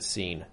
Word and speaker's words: Sean: It was spelled S-E-A-N, but like he and his family pronounced it Scene.